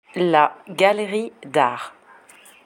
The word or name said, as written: la galerie d'art